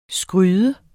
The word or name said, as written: skryde